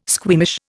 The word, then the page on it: squeamish: Indian